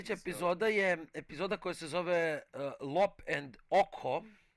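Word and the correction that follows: Ochō: I'm thinking that's how you pronounce it. Premijerno prikazana 22. septembra 2021. godine sa prosječnom ocenom 7,3. – Očo, ali da. – Očo. – Da. – Dobro, dobro, hvala vam na ispravci, to je fair.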